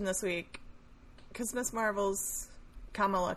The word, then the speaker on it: Kamala: So, So the vice president, you say her name like the punctuation first. Kamala.